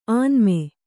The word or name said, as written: ಆನ್ಮೆ